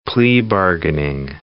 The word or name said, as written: plea bargaining